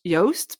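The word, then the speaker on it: Joost: So in Dutch, his name is pronounced Yoast, but people would always pronounce it wrong because everyone would say Yoast the way you write it in Dutch.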